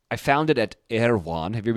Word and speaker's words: Erewhon: It's spelled really weird, but everyone pronounces it Erewhon.